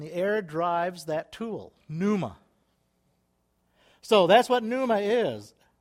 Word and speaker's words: pneuma: The Spirit here, when you're looking at that, the meaning of it and the way it would be pronounced, it comes out of the Greek, would be pneuma. Pneuma.